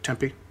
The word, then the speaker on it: Tempe: And recently, I think it was this week, he had a devotional in Tempe, Arizona. That's how you say it, right? Tempe? – Mm-hmm.